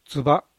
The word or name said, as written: tsuba